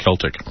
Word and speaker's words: Celtic: Or is it pronounced Celtic? – Yes.